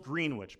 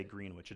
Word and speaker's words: Greenwich: Greenwich, Connecticut, if you don't know, it's spelled Greenwich, but they're so uptight that they can't even say Greenwich. It's Greenwich. Greenwich, Connecticut is immaculate.